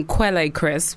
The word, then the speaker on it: Quelle Chris: And he pronounces his name Quelle Chris, which is always confusing because the word Kel is French.